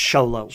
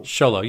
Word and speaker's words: Xolo: Zolo? – Marduena? I think it's pronounced Sholo. – Sholo. Yeah, he is the... – X-O-L-O.